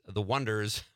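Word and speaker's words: Oneders: that thing you do about a Beatles era band called, who was a one-hit wonder, pronounced The Wonders, but it was written as, I think they pronounced it The Oneaters.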